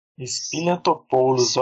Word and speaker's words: Spiliotopoulos: E traz aqui, junto com Evan Spiliotopoulos, falei certo? – Spiliotopoulos, ó.